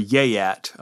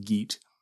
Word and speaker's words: Geat: Rather, Beowulf is a Geat, or a Yeat, I've heard it pronounced both ways, but I'm going to stick with Geat, which is a member of a tribe that inhabited what is now southern Sweden.